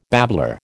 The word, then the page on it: babbler: American English